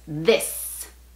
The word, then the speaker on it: this: Y la razón, yo creo, es porque vemos la I y queremos pronunciarla como la I en español. Y eso sería this, pero no. Es this.